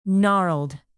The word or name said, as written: gnarled